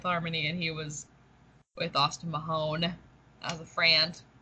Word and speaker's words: Austin Mahone: I said that wrong. Austin Mahone. I think I said it wrong earlier too. I think I said Austin Mahomes.